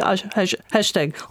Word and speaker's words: hashtag: Une prononciation, on a une prononciation plus anglaise, mais c'est pas vrai, mais on pense, on dit hashtag, on prononce le H.